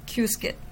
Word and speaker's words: Qiskit: The second component is Qiskit, and it is quite a controversial topic of how we pronounce it, but I stick to what the Qiskit community members calls it themselves, and they say it Qiskit.